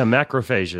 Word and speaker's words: macrophage: And it has the ability to, from what I understand, and this is going to be in your wheelhouse, it activates macrophage. Is that how you pronounce that activity? – Yeah, macrophage is an immune system component, yeah.